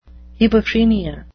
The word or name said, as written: hebephrenia